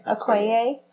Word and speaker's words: Okoye: It sounds like the scenes that were deleted had a little bit more to do with our Okoye, I would say her name. – Okoye.